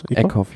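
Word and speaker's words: Eekhoff: Eckhoff, ja.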